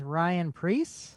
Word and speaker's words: Ryan Preece: I apologize, Ryan Preece.